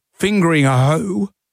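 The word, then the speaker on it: Fingringhoe: Spare a thought for the people over in Essex at Fingering Hoe.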